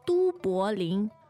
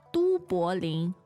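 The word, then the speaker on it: Du Bolin (都柏林): How do you say the word Dublin in Chinese? – Du Bolin. Du Bolin. – Du Bolin. What are the tones on that? – First, second, and second.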